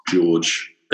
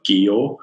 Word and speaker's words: George: But I think in Denmark they pronounce George, they don't have the E on the end and it would be like Gior, something like that. So people would be shouting like Gior, like go on.